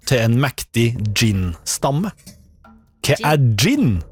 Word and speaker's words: jinn: Nei, ja, det skrives med jod. J-I-N-N. – Oh. – Gin. Men okay. Også det uttales gin. For på engelsk skrives det med D først. Gin.